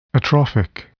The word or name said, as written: atrophic